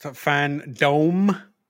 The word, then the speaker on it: Fandome: Also, it's pronounced Fandome. Please. – Oh, it is? – Dome. – Fandome. – Fandome. – It's a Fandome. – It's a dome of fans, if you will.